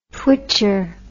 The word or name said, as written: twitcher